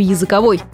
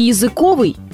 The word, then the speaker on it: языковой: На самом деле вся путаница в том, что оба ударения, и языковой, и языковый, верны, но каждое при своем значении. Языковой может быть исключительно колбаса, поскольку она приготовлена из языка.